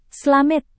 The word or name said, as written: slamet